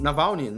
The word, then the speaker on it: Navalny: É, Nalvene, Nalvene. Navalny. Navalny, Navalny, falei errado.